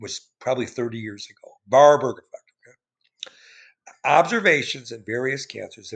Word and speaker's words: Warburg: Warburg, I'm saying, it's trying to say it in German. It's spelled W-A-R-B-U-R-G, right?